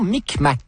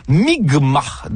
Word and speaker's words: Mi'kmaq: la nation Micmac, qui se prononce en fait Mi'kmaq, dans ce qui sera le premier contact.